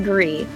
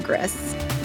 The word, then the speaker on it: Gris: This is what it said, classic Mac OS, and yes, it is pronounced Gree, not Gris.